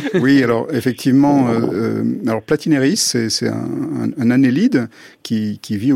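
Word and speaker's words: Platynereis: Une forme ancestrale de cerveau avec l'exemple d'un petit ver marin qui a 600 millions d'années, le ver Platineris. Je ne suis pas tout à fait sûr de sa prononciation. – Platineris, oui.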